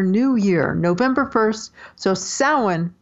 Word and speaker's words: Samhain: But it's not spelled anything like it sounds. It's spelled S-A-M-H-A-I-N, spelled Samhain, but it's pronounced Samhain.